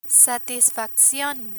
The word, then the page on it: satisfaccion: spanish